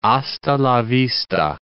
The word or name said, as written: hasta la vista